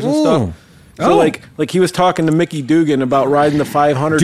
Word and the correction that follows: Doohan: It's Doohan. – Doohan, whatever. – Hey, it's Doohan. Mickey Dugan's a British comedian.